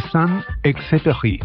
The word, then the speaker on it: Saint-Exupéry: El célebre escritor y aviador francés Antoine de Saint-Exupéry. No sé cómo se dice. – Antoine, ya te ayudo. Antoine de Saint-Exupéry.